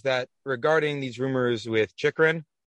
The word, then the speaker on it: Chychrun: Pronounced his own name as Chychrun on Elliot Friedman's podcast.